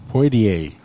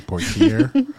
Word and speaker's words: Poitier: Poitier. Okay, I said it wrong. Poitier.